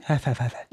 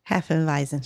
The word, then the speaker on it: Hefeweizen: Hefeweizen? – Hefeweizen. – Yeah, you got to say it correctly. Hefeweizen. – Hefeweizen.